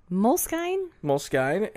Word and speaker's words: Moleskine: The moles, M-O-L-E-S-K-I-N-E. Mm-hmm. How did you pronounce it? – Moleskine, and I didn't know how to pronounce it.